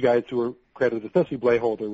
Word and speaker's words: Blaeholder: B-L-A-E-H-O-L-D-E-R, Blayholder. – Okay. – Also, George Yulee, that's G-E-O-R-G-E, Yulee.